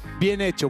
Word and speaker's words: bien hecho: Is it buen hecho? – Bien hecho, well done.